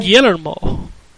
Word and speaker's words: Guillermo: You gotta put the Spanish twist on it. – Guillermo? – Guillermo.